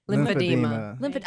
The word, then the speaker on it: lymphedema: Lymphedema.